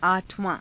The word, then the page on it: aa_thavaa~: Hindi